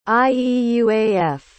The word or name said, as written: ieuaf